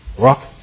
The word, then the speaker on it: ruach: R-U-H-C-H. Remember that word? Ruach. And he says, voice reminds us that it's even to be spoken with a breathy sound.